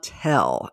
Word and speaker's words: Toelle: I've got Brad and Pamela Toelle, and I learned early on in the interview that I've been mispronouncing their last name as long as I've been them. It's spelled T-O-E-L-L-E, and I guess it was, in my mind, it's like things in San Antonio or Texas, you know, where it's spelled a certain way and you try to pronounce it that way and then you don't pronounce it that way.